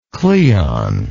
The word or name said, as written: Cleon